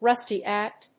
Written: rusty at

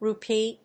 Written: RUPEE

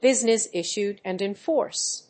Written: business issued and in force

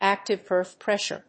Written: active earth pressure

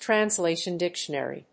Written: translation dictionary

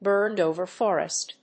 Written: burned‐over forest

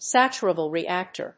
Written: saturable reactor

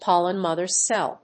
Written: pollen mother cell

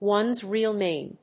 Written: one's real name

